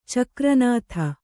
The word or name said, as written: ಚಕ್ರನಾಥ